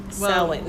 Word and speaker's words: Samhain: Turn the M upside down to make it a W. Samhain.